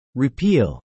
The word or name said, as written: repeal